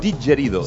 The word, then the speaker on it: didgeridoo: I es diu digerido. – Sí, digerido. – G-E-R-I-D-O-O. – Mira, com el castellà digerido, però amb una D. Digerido, i pronunciat a l'australiana.